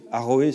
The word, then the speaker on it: Aroé: Aroé, ça s'écrit A-R-O-E, Bopé, B-O-P-E.